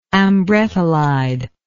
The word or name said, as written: ambrettolide